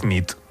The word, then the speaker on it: nit: Y noche es nit. – Muy bien. – Bona nit. Bona nit, es verdad. – Bona nit.